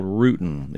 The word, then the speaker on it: rutin: It is the main analog that makes quercetin quercetin, but it's called rutin. It's spelled R-U-T-I-N. – R-U-T-I-N. I-N. Okay. – It almost, if I use it in a sentence and spelled it the wrong way, I'd say I'm rooting for your granddaughter. – Okay. – That didn't come across real well. But rutin is R-U-T-I-N.